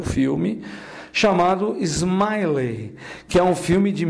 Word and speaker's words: Ismaily: E o Smiley tem uma história engraçada, porque o nome dele, Smiley, se escreve com I-S-M-A-I-L-Y. Smiley.